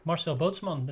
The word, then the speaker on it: Marcel Bootsman: Yeah, you pronounce this pretty good. Marcel Bootsman, that's how I say it.